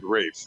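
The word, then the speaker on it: Ralph: He spells his name the same as I do, right, but they pronounce it Rafe.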